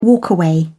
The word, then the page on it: walkaway: British English